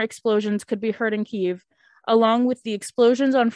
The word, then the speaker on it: Kyiv: Ukrainian capital Kyiv, I hope I'm saying that right. – Kyiv.